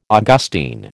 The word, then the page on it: Augustine: American English